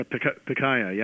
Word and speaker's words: Pikaia: I'm going to try to pronounce, Pikaia? – Yeah, Pikaia, yeah.